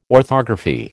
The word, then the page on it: orthography: American English